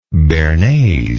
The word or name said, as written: bearnaise